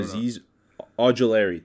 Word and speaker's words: Azeez Ojulari: I don't Azeez Ojulari.